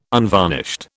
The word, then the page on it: unvarnished: British English